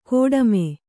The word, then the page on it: ಕೋಡಮೆ: kōḍame